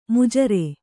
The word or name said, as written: ಮಜೂರ